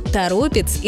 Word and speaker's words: Торопец: Название города связано с рекой Торопа, от которой оно и образовано, поэтому ударение сохраняется в корне слова «Торопец».